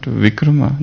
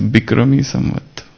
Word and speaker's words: Bikrami Samvat: In Nepal they pronounce V as B. So V becomes B. So that's not Vikrama, that is Bikrami Samvat.